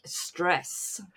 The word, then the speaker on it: Cres: But this particular island I want to mention, it is spelt C-R-E-S. – Ah, yes. – But it is pronounced something more like stress.